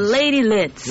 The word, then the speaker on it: Lydz: And it's at the JCC, and there's lots of artists on, like Tanya Mullings, Jimmy Reed, yourself, Lady Lights. How do you say that name? Lids or Lights? – Lady Lits. – Lady Lits. All right, L-Y-D-Z.